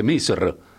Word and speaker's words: Misr: Que han sido, por ejemplo, Egipto, en la lengua original, ¿cómo se dice? Misr. Y nosotros nada que ver, decimos Egipto.